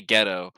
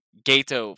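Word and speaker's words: Geto: I was gonna say Ghetto, but Gato. Fuck it, that man.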